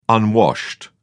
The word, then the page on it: unwashed: British English